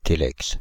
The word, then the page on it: télex: Paris; [te.lɛks]; France (Île-de-France); /te.lɛks/